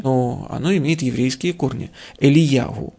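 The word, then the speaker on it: Элияху (אליהו): Нет, Илья, ну просто и сами евреи его произносят чуть-чуть по-другому. Они его произносят Элияху. – Угу. – Элияху.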